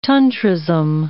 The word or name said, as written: Tantrism